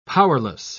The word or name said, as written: powerless